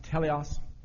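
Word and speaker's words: teleos (τέλειος): It's pronounced teleos.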